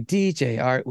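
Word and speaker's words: DJ Artt: DJ Art, with two T's for a double dose of that tink tink. The D is silent, so it's just Jart.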